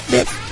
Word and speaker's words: death: Es un juego de palabras en japonés, porque la palabra en inglés se escribe D-E-A-T-H. La T-H tiene un sonido muy peculiar, y para pronunciarlo bien, tienes que pegar la lengua al paladar y a los dientes de la parte de arriba, death.